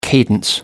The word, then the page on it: cadence: American English